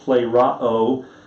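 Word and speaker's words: plero (πληρόω): It's pronounced plero. It's spelled P-L-E-R-O-O, plero, and it means to be fulfilled.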